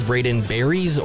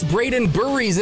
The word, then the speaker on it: Braden Burries: unlike almost every other sports radio host who calls Braden Burries Braden Berries or Braden Burris. It's like, look, I don't ask for much, but just one freaking Google search. That's all. Just one Google search is all it is. It's Braden Burries. It's literally just how it's spelled.